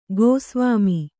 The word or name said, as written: ಗೋಸ್ವಾಮಿ